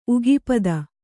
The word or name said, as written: ಉಗಿಪದ